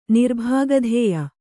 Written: ನಿರ್ಭಾಗಧೇಯ

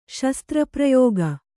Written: ಶಸ್ತ್ರಪ್ರಯೋಗ

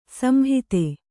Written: ಸಂಹಿತ

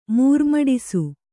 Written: ಮೂರ್ಮಡಿಸು

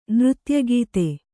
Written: ನೃತ್ಯಗಾತಿ

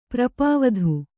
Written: ಪ್ರಪಾವಧು

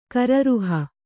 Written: ಕರರುಹ

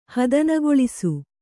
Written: ಹದನುಗೊಳಿಸು

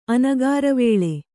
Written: ಅನಗಾರವೇಳೆ

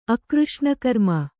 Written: ಅಕೃಷ್ಣಕರ್ಮ

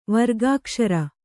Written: ವರ್ಗಾಕ್ಷರ

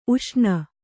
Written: ಊಷಣೆ

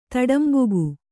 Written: ತಡಂಬುಗು

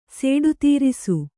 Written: ಸೇಡುತೀರಿಸು

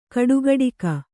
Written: ಕಟುಗೊಡಗಿ

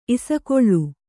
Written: ಇಸಕೊಳ್ಳು

